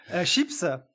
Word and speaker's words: chips: Chips, S se prononce nom féminin pluriel, 19e siècle emprunté de l'anglais chip.